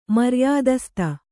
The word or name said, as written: ಮರ್ಯಾದಸ್ತ